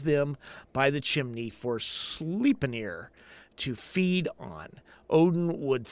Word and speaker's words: Sleipnir: I'm pronouncing that absolutely correct. – Yeah. – Sleipnir. – Sure. That's the way I'd say it.